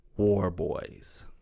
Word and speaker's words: Warboys: And without further ado, here is my conversation with Kelsey Warboys.